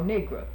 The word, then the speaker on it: Negro: I was taught to pronounce N-E-G-R-O, nigra. That was the polite, southern, educated pronunciation of the word.